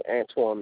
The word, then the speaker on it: Antwon: It's Antoine McFadden. – Can you spell your first name, please? – A-N-T-W-O-N.